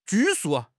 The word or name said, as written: 拘束